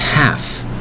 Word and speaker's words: half: Today's word is half, H-A-L-F, half. Now you'll notice the L in the spelling of this word is silent. You are not hearing the word L. You're not. However, it is silent when you spell it. It's pronounced half, H-A-L-F. That's right, one syllable word.